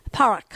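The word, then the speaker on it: Padhraic: It's actually pronounced Parak.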